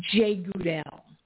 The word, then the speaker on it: Jegudiel: Jegudiel. It's sometimes spelled Jegudiel. Sometimes it's got an H instead of a G, but the most I could find yesterday is it's pronounced Jegudiel.